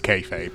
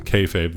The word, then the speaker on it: kayfabe: It's pronounced kayfabe, yeah. Kayfabe, that's the one.